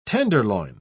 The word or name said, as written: tenderloin